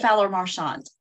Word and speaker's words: Fowler-Marchant: Did I pronounce that correctly? – Close enough. – Okay. – It's Fowler Marchant.